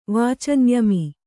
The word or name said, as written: ವಾಚಂಯಮಿ